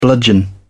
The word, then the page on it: bludgeon: British English